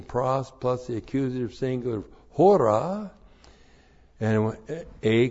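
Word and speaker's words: hora (ὥρα): H long O R A, hora.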